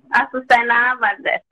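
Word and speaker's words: Asusena Valdez: Azucena Valdez. A-S-U-S-E-N-A. And then my last name, Valdez. V for Victor. A-L-D-E-Z.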